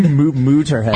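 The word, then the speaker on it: Motörhead: You guys know what big fans I am of both Megadeth and Motorhead. – Yeah. – Was that how you say it with the little umlaut over the O? Motorhead. Motorhead. – Mooterhead. – Mooterhead. Yeah, see, I love this song.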